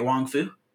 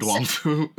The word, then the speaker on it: Wong Fu: Pronouncing that right, Wong Fu? – Yeah.